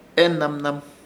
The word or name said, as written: Enanman